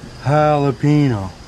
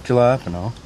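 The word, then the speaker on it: jalapeño: The J's like an H, Ricky. Jalapeno. Not jalapeno.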